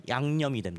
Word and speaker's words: 양념: 그래서 약과 염이 합쳐지면 양념이 됩니다. – 아, 그래서 오늘날이니까 어떤 음식을 할 때 간을 맞추기 위해서 넣는 것을 양념, 물론 이응을 쓰지만 발음이 바뀌어서 그런 것이고 옛날 약과 염, 즉 꿀과 소금이었던 것이죠.